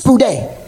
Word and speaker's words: spoudē: In Greek, it's called spoudē. It's pronounced spoudē.